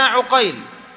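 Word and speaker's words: عُقيل: الآن عندك عقيل، عين قاف يا لام، صحيح؟ هذا كتابته. وعندنا عقيل، عين قاف يا لام، صحيح؟ لكن الخط واحد. لما تأتي تنطق، إذا أردت أن تنطق الأول بالفتح والثاني بماذا؟ بالضم.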